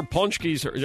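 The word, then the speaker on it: Pączki: They used to deliver Punchkis, am I saying it right, Rita? – Yes, you are.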